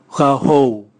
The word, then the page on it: gahou: Raga